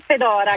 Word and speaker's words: Fedora: Sì, Fedora.